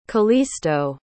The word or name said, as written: calisto